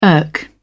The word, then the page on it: irk: British English